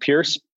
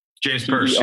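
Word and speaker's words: Perse: How do you say that LA company? Is it Pierce? Percy? James Purse? – James Purse, yeah.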